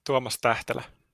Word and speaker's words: Tuomas Tähtälä: So how would you say your name properly? – Tuomas Tähtälä. – Tähtälä. Okay, so the H does actually mean something.